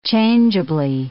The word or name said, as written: changeably